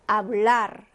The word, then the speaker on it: hablar: 자, 그러면 이때는 먹지 않고, 영어처럼 이렇게 R 하고 먹지 않고 그대로 발음해줍니다. 그래서 어떻게 읽으시면 돼요? Hablar 라고 읽어주시면 돼요.